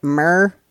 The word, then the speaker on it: myrrh: I always wonder if I'm saying that right. Myrrh, myrrh? – Yeah, that's correct.